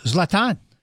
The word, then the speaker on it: Zlatan: I call him the special Z, but Zlatan.